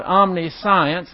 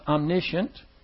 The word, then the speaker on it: omniscient: By the way, that is not omniscience. That's the way it's spelled, but it's pronounced omniscient.